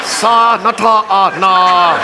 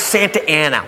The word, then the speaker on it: Santa Ana: Sa Nata Na. How? It's Santa Ana.